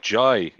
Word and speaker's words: Jay: He called himself Jai, even though it's spelled J-A-Y.